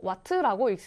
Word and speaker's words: 와트: 소비전력은요, 단위를 W라고 쓰고 와트라고 읽습니다.